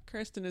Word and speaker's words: Kirsten: What is your preferred pronunciation? – Kirsten is the only one that I'll accept.